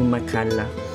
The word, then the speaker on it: Machala: Alors pour le dire version française, je dirais Tommaso Macalla, qui s'écrit M-A-C-H-A-L-A, donc en italien plutôt Macalla.